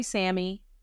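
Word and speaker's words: Sammi: My mom calls me Sammy. Spelled with an I, put some respect on my name, not S-A-M-M-Y, S-A-M-M-I.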